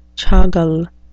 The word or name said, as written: chagal